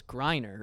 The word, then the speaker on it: Griner: But on stand up, but I was talking with somebody about Brittany Griner. Grimer. And I was like, she's like the most well-known WNBA player, and I kept calling her Brittany Grimer, and then he's like, you know, it's Griner, right?